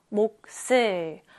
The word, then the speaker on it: 몫을: 목슬. 그러면 뒤에 있는 자음 시옷은 뒤로 옮겨가서 목슬 이렇게 읽어야 하는데 목슬, 목슬 계속 반복해서 읽다 보면 선생님 목슬인 것 같아요. 쌍시옷이요. 그래요. 바로 이렇게 목슬이라고 읽어야 자연스럽습니다.